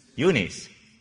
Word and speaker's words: Eunice: Well, how do you pronounce that? Eunice. Okay.